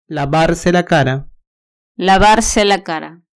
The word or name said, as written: lavarse la cara